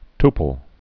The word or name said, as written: tuple